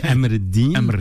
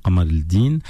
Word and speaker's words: Qamar Eddin: Alors nous on dit Amr Eddin libanais, et on pourrait dire Qamr Eddin en arabe un peu plus littéraire, ou Qamar Eddin.